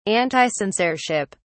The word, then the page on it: anticensorship: British English